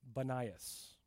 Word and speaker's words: Banias: They don't pronounce the word P in Arabic, so they named it Banias.